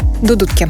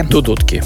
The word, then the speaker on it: Дудутки: Правильно ударение, помогайте мне, если что, в Дудутки. – Дудутки. – Дудутки, спасибо, да.